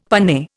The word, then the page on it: panne: Indian